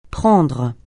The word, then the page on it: prendre: francais